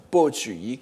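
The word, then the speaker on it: Po Chü-i: Et vous savez qu'en France, en France de longue date, la prononciation de ce nom est Po, P-O dans l'ancienne transcription, B-O actuellement. Je préfère pour ma part donc la transcription Po Tzu Yi, qui est la transcription classique bien attestée.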